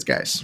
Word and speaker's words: Guice: Um, it, to me, this is a coin toss between Chubb and I've always called him Juice, but um, how do you actually pronounce his name? Guice. I think it's Guice.